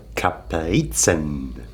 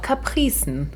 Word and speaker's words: Kaprizen: Ist dieses Mal Kaprizen. – Oder auch Kapricen. – Da sind wir uns noch nicht ganz sicher, beziehungsweise wir lassen mal beide Aussprachen gelten. – Es kommt halt drauf an, welche Sprache. Im Deutschen schreibt man es tatsächlich mit K und mit Z. Aber es kommt wohl aus dem Französischen, wobei vielleicht wieder aus dem Lateinischen mit C und C. – Capricieux. Aber ich finde auch ein Z kann man ruhig scharf Kaprizen aussprechen.